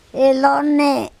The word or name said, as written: elOne